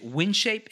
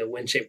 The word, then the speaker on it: Windshape: Is it Windshape? Is that the name of the organization? – Yes, sir. Windshape Foundation, specifically in the Windshape College program. So it's Windshape, like W-I-N.